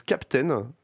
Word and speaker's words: KPTN: Alors je pense que ça se prononce Captain, c'est écrit KPTN, et donc c'est en Creative Commons by SA.